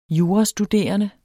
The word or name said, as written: jurastuderende